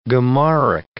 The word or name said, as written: Gemaric